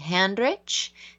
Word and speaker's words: Handrich: If you go to Facebook, my full name is Debbie Handrich. It's D-E-B-B-Y. And my last name is spelled just like it sounds, H-A-N-D-R-I-C-H.